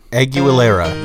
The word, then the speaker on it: Aguilera: I'd like to bring up another little pop star named Christina Aguilera. Am I pronouncing that right? – Aguilera, yes.